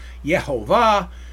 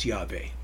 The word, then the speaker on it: Yahweh: I have a whole reasoning behind why I say it's Yahweh. Some say Yehovah, and others have other ways of saying God's name. I happen to say that the pronunciation is Yahweh, but that's not a hill I want to die on.